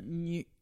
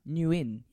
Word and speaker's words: Nguyen: Thank you very much to Patreon subscriber, Anthony Nguyen. Is that how you pronounce it? – Nguyen. – Yes. – Nguyen. Yeah. – Nguyen. Is it Nguyen? – Something along those lines.